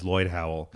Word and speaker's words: Lloyd Howell: Well, I chose my Twitter username, which is at Void Howler, because it rhymes with my real name, which is Lloyd Howell.